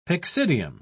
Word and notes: pyxidium: British English

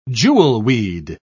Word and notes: jewelweed: British English

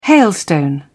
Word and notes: hailstone: British English

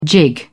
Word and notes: jig: British English

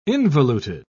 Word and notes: involuted: British English

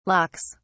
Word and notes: locks: British English

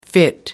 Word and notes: fit: American English

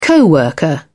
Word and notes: co-worker: British English

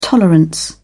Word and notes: tolerance: British English

